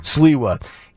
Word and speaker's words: Sliwa: They have a host by the name of Curtis Sliwa, I believe is how you say his Sliwa. – Name. Sliwa.